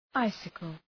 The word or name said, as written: icicle